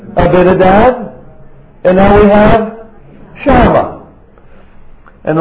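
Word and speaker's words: Shammah: I'm glad nobody said Shamu. No, Shama, not the killer whale, but this is one of his sons.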